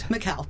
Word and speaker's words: Mikkel: Mikkel, but close. – Mikkel. – You have to put the accent on the right syllable.